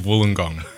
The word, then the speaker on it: Wollongong: The University of Wollongong.